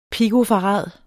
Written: pikofarad

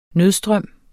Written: nødstrøm

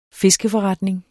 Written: fiskeforretning

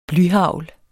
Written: blyhagl

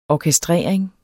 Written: orkestrering

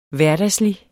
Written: hverdagslig

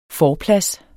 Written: forplads

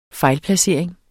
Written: FEJLPLACERING

